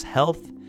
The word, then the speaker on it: HLTH: HLTH, pronounced health,